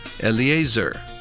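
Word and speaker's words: Eleazar: 퍼네이하스 이렇게 발음하시고 그 다음 엘르아살은 엘리에이저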